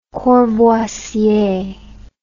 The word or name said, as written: Courvoisier